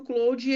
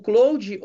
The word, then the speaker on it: Claude: O Claude, ou Claude, como muitas pessoas falam, né? O nome Claude da Anthropic por conta ali de uma pronúncia em francês, mas é uma empresa americana também.